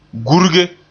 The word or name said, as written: gurge